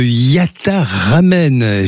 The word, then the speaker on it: Yatta Ramen: Vous êtes cofondateur et président de Yatta Ramen. J'ai bien dit, hein, je l'ai bien prononcé. – Exactement, vous c'est parfait.